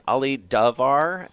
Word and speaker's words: Ali Davar: We're lucky to have with us Ali Davar.